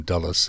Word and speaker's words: Dulles: The name originally was Douglas, but they became Dulles in the pronunciation. Of course, the G is often aspirated in Irish.